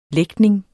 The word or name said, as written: lægtning